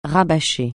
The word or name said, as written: rabâcher